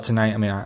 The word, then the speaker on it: TNGHT: You call it Tonight. It's Tonight missing every vowel possible.